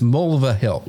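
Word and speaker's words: Mulvihill: it's Mulva Hill.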